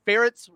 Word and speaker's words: Ferentz: Nobody knew how to say his name, but yes, Ferentz rhymes with parents.